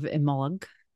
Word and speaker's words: Imbolc: In honor of Imbolc, that's more of an Irish pronunciation. You might see it written more I-M-B-O-L-C. So you might hear it pronounced Imbolc or something similar.